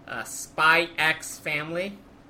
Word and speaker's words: Spy X Family: Spy X Family. Yes, you pronounce the X.